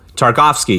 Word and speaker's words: Tarkovsky: And this is the 1969 Soviet biographical historical drama directed by Andrei Tarkovsky. Tarkovsky, yep, and co-written with Andrei Konchalvsky.